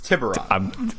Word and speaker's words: Tiburon: The Tiburon? Tiburon. – Tiburon. – There's many pronunciations. – No, there's not. It's one. It's Tiburon.